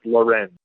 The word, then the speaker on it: Lorenz: I know the last name is pronounced Lorenz, but yeah, I think it's I think it's Rieger Lorenz.